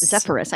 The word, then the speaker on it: Zephyrus: Zephyrus. I think that's what yeah. it is. That's what uh-huh. how I have it uh, phonetically, whatever.